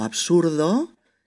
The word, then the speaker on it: absurd: Suena esa T final en lugar de la D. O absurdo, que es absurd.